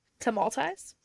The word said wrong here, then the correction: Tamalpais: Okay, great. – No, tamal pies.